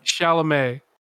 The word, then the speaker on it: Chalamet: Blanchet? Did you say Blanche? You mean Timothée Chalamet? – Timothy Blanchet. Yeah, Timothy Blanchet. Isn't that how you say it? – Chalamet. – Timothy Chalamet, the god.